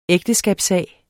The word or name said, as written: ægteskabssag